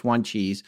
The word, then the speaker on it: Wanchese: W-A-N-C-H-E-S-E, but we looked at Algonquin pronunciation chart, and I'm pretty sure it's Juan Cheese.